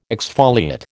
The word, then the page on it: exfoliate: American English